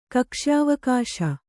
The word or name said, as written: ಕಕ್ಷ್ಯಾವಕಾಶ